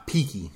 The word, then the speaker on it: Peaky: Peaky rhymes with cheeky.